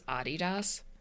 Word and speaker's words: Adidas: Did you just call Adidas Adidas? – That's how it's pronounced. It's short for Adi Dassler, who was the person that founded it.